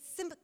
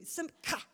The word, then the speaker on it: simcha: And there's a couple of words for joy, one of them that really spoke to me, and it said simcha. That's how you say it. I even listened to the Hebrew, simcha.